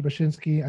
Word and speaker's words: Bachynski: And thank you for pronouncing my last name correctly. That was correct. Thank you very much. My name is Josh Bachynski.